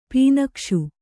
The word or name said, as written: ಪೀನಕ್ಷು